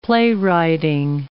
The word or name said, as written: playwrighting